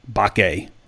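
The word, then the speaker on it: Mbake: Arkansas's class is pretty much full except for those two guys and Samuel Bakke. That's a silent M. So it's M-B-A-K-E, Samuel Bakke.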